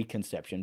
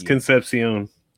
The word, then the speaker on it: Concepcion: I hope I say that right because I just love how that is. – It is. – It could very well be Conception, but I like Concepcion. – It's Concepcion.